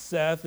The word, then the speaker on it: Seth: Seth, or Set, but it's pronounced Seth, is the god of storms.